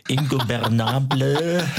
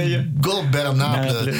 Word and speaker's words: Ingobernable: Ingobernable. – Spanisch, ne? – Ja, Ingobernable.